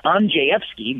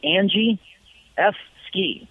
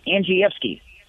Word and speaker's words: Andrzejewski: It's not easy being an Andrzejewski, Kim, so that's actually how we say it. We try to help out. In Polish, it's pronounced Andrzejewski, but we just say Andrzejewski, Andrzejewski.